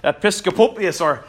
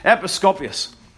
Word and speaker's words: Episcopius: Their leader was a man called Simon, get this name right, Episcopius. It's one of those impronounceable names, Episcopius.